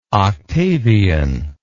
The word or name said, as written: octavian